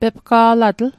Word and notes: pepga'latl: Mi'gmaq

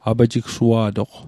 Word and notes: apajigsua'toq: apajiksua'toq

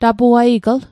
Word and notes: tapuaigl: Mi'gmaq

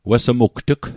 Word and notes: wessmugt'g: Mi'gmaq

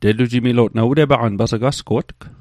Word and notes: asgo'tg: Mi'gmaq

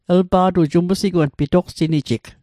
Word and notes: umsigwang: Mi'gmaq

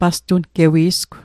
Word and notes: pastungewi'sgw: Mi'gmaq

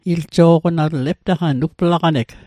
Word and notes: iljo'qonatl: Mi'gmaq

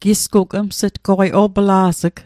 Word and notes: opla's'g: Mi'gmaq

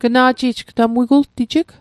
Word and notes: gna'ji'j: Mi'gmaq

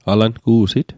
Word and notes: alangu'sit: Mi'gmaq

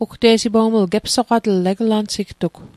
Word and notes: gepsoqatl: Mi'gmaq

